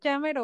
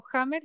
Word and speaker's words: Jamer: Eh, sí, Jamer o Hammer Yunuen.